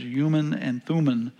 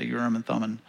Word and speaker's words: Urim and Thummim: The Hebrew way of saying is Urim and Thummim. But that sounds odd to me, so I'm just going to say Urim and Thummim.